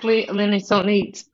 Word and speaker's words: Lencioni: I can always never pronounce his last name. – Lencioni. – Lencioni. There's five of them. Yeah, if you had a little bit of Italian, so, it would be easier for you, right? – Yeah, I know.